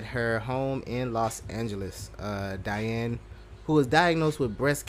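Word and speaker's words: Diahann: Duh, when you pronounce it, Diane. – Yeah, it doesn't like Diane. – No, it is Diane. – Oh, it's sure? – Yeah. – Oh. – You don't pronounce the H.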